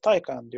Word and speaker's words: Taycan: Ama e, okunuşu yani biz bunu Porsche yetkililerine de sorduk. E, Selim Bey'e de sorduk. Yurt dışındaki tüm videoları da Porsche'nin e, izledik. E, ismi, okunuşu onlar e, Taycan diyorlar ve Taycan olarak biz de e, söylemek durumundayız.